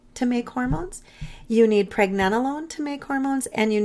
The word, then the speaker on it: pregnenolone: So those other um, hormones you mentioned earlier, DHEA, and is it pregnant? How do you say that? – Pregnenolone.